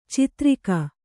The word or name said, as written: ಛತ್ತರಿಗೆ